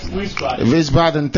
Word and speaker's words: Wiesbaden: Wiesbaden. Is that correct? I want to get the name correct and the pronunciation as well. Am I correct? – Wiesbaden.